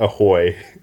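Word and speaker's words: ahoj: Apparently it's pronounced like ahoy.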